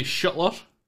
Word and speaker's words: Schuttler: Stephanie Schuttler.